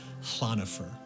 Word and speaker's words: Llanfor: So I'm sure I irritated everyone mightily because it's Llanfor is spelled L-L-A-N and then O-F-F-O-R. And so the double L in Welsh is actually, to get very linguistically technical, it's aspirated. So it's Llanfor.